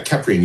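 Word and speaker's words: Kheprian: Yeah, Keprian, you've got it correct. And a lot of people pronounce it wrong.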